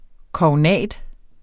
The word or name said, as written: KOGNATENS